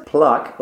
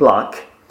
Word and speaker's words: plaque: Because I know we use it in Czech, but it actually is an English word, and it is pronounced plaque, and spelled P-L-A-Q-U-E, plaque.